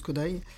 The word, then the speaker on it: Kodály: Et là, elle est partie sur Bartok et Kodály, Béla Bartok et Zoltán Kodály, qui s'écrit Kodály mais qui se prononce Kodály.